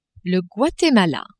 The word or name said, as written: Guatemala